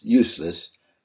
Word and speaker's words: Ulysses: Useless's Old Dog was aired on Kentucky Educational Television in a program of a videotape interview with Ulysses, pronounced Useless, Vanover, of McCreary County, Kentucky, and aired on January 5, 1977.